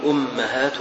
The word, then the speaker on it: أمهات: قال فيها لغات الهمزة والميم فيهما الضم والكسر، تقول ضم الهمزة وكسرها وفتح الميم وكسرها، الهمزة فيها الضم والكسر والميم فيها الفتح والكسر، فتقول أمهات وأمهات وإمهات وإمهات،